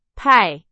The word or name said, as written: 派